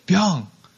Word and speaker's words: Byung: Five letters, one syllable. That's pretty hard, right? B-Y-U-N-G, Byung.